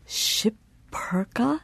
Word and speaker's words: Schipperke: Schipperke. – It's a serious disadvantage when the moderator can't even pronounce the word. – Oh, my. Schipperke? Is that what you said? Schipperke. Mm-hmm. Oh, okay. So there's some crazy vowels or crazy consonants in there. S-C-H.